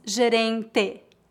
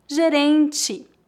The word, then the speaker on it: gerente: Lembre-se que a vogal E é pronunciada como I em final de palavras. Não dizemos gerente, e sim gerente.